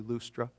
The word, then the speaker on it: Lystra: They went on to, you all pronounce it Lystra, right? It's actually Lustra, but we'll go with Lystra.